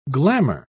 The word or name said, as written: glamor